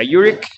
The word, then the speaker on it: Urich: Yo le digo Urich porque siempre le he dicho así, pero realmente se pronuncia Urich.